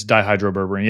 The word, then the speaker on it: dihydroberberine: It's dihydroberberine.